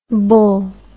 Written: bool

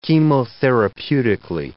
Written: chemotherapeutically

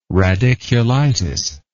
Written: radiculitis

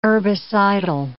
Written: herbicidal

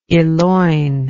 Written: eloinment

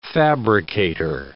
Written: fabricator

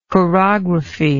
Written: chorographically

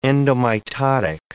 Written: endomitotic

